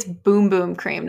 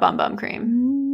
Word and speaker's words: Bum Bum Cream: Apparently, it's Boom Boom Cream, not Bum Bum Cream. – No way. – Yeah. – Miss me with that. But it's spelled like bum bum.